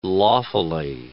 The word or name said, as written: lawfully